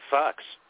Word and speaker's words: Fuchs: Fuchs, and I noticed that there's a lot of, and it's spelled F-U-C-H-S. I'm reminded of this the other day because there's a senator. And they were having a hard time pronouncing his name on Fox. And it turns out that he actually insists on pronouncing his name Fox.